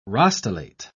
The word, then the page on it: rostellate: British English